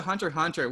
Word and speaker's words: Hunter x Hunter: So yeah, that brings us to Hunter x Hunter, which the X is silent.